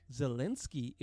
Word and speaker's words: Zelensky: Zelensky is mm-hmm.